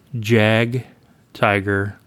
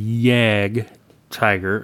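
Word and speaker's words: Jagdtiger: And I have been saying Jag Tiger and Yag or Jag Panther. The J is a Y, basically. – Yeah.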